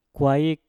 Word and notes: kwaik: Mpur

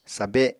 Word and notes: sabe: Apatani

